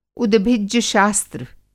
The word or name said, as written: उद्भिज्ज शास्त्र